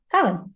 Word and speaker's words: Samhain: So, as with most Irish words, it does not get pronounced how it appears. So it's Samhain. – Yeah, because the MH often in Irish can have kind of a W sound. Usually it's either a V sound like in Neve, or a kind of W sound like in Samhain.